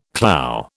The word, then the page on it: clough: British English